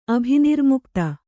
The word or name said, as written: ಅಭಿನಿರ್ಮುಕ್ತ